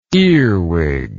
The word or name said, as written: earwiggy